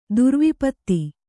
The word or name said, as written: ದುರ್ವಿಪತ್ತಿ